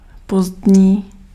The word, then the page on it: late: /leɪt/